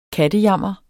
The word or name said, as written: kattejammer